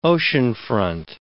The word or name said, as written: oceanfront